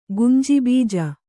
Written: ಗುಂಜಿಬೀಜ

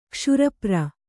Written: ಕ್ಷುರಪ್ರ

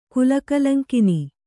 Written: ಕುಲಕಲಂಕಿನಿ

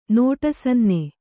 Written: ನೋಟಸನ್ನೆ

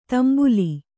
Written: ತಾಂಬೇಲು

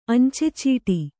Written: ಅಂಚೆಚೀಟಿ

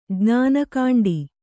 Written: ಜ್ಞಾನಕಾಂಡಿ

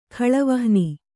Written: ಖಳವಹ್ನಿ

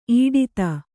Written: ಇಡಿದು

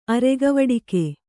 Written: ಅರೆಗವಡಿಕೆ

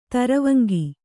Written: ತರುವಂಗಿ

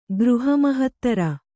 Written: ಗೃಹಮಹತ್ತರ

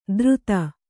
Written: ದ್ರುತ

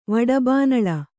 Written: ವಡಬಾನಳ

